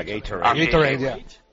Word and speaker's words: Gatorade: Me dice Parra, yo no lo sabía, que Gatorade, que se pronuncia Gatorade, que se mofó de LeBron James.